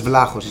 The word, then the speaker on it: Vlahos: Panos Vlahos is my name.